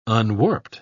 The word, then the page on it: unwarped: British English